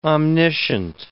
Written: omniscient

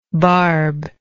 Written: barbe

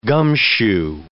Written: gumshoe

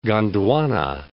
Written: Gondwana

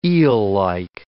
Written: eellike

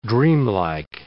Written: dreamlike

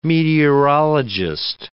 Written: meteorologist

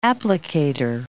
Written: applicator

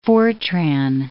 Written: FORTRAN

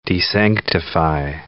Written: desanctify

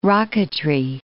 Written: rocketry

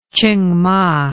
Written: chingma

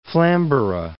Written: Flamborough Head

